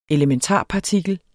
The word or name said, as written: ELEMENTARPARTIKLER